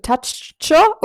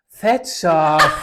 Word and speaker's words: Thatcher: Thatcher. – Das ist ein deutsches Wort. Was ist das denn? – Margaret Thatcher war die Premierministerin.